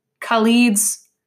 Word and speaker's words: Khalid: And Khalid's, did I say that right? – Yeah.